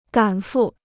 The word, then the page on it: 赶赴: gǎn fù